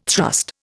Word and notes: thrust: Indian

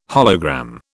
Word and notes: hologram: British English

